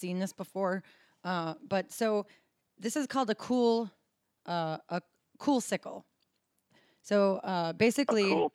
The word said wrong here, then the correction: coolicle: a coolicle. – Oh, okay. – Sorry, I kind of mispronounced that. A coolicle.